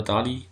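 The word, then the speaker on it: Dæhli: Aber solche Mats Müller-Daly wird, oder Dali, wird ausgesprochen.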